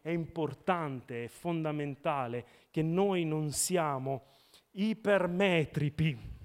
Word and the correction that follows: ipermetropi: Ho detto giusto stavolta, no? Ipermetropo. Vabbè, il plurale. Ipermetropi.